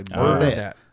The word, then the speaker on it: Burdette: You have to pronounce it correctly. Burdette. – Burdette. – We'll leave the light on for you. – But the et throws me off completely. – Burdette. – Like Bernadette? – Yeah.